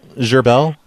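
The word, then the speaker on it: Gherbel: Gerbel. – And how do you spell that? – It's G-H-E-R-B-E-L. – You said that was G-H or Z-H? – G, as in gnome. – G-H-A-R? – Yep. B-E-L.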